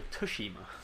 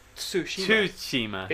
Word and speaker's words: Tsushima: Ghost of Tsushima is how they pronounce it in the game. – Tsushima.